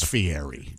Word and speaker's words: Fieri: It's Fieri.